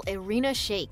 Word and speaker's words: Irina Shayk: This is how you pronounce her name. – Model Irina Shayk.